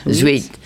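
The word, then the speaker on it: huître: Sweet. – Sweet? – Sweet. She's oysters. Talking about oysters. Uh-huh. For you that don't speak French, it spells H-U-I-T-R-E, sweet.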